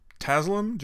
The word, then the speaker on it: Taslim: I think it's pronounced Taslim, Joe Taslim from the Raid, Redemption, and the Raid.